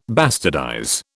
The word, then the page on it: bastardize: British English